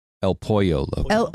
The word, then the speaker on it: El Pollo Loco: Did I say it wrong? – El Pollo Loco. – El Pollo Loco. – Oh, my God, your gringa is showing. Yes. Yes, it is.